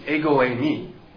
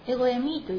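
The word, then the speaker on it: ego e me (ἐγώ εἰμι): And in Greek it's ego e me. – ギリシャ語ではエゴエミという発音です。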